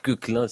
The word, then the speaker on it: Koechlin: Quéclin et pas Quéclin, sinon vous avez l'air d'un affreux ringard.